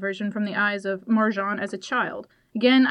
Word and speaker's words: Marjane: Author, I might hurt pronouncing this, but author Marjane Satrapi.